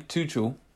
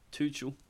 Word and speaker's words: Tuchel: Tuchel. Tuchel.